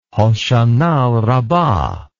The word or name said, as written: Hoshana Rabbah